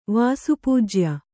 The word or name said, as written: ವಾಸುಪೂಜ್ಯ